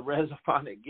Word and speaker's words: resophonic: What is a resophonic guitar player, if I'm even saying it right? – No, that's it. You're saying it right.